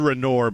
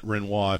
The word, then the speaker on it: Renoir: It's spelled Renoir, but they pronounce it Renoir if they want to be fancy.